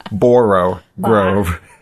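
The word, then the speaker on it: Borogrove: Which the way I help remind myself how to pronounce it is remind myself how boring it was to see Boro Grove. B-O-R-O, by the way, is how it's spelled according to the subtitles.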